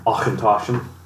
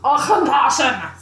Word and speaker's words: Auchentoshan: How do you say that? – Auchentoshan. – Auchentoshan!